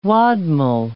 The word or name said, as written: wadmol